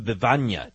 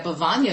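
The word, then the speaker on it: Bevagna: I want to know about the birds of Bivagna. Did I say this correctly? – Oh, yes, you did. Well pronounced. I appreciate that. Yeah, Bivagna is beautiful.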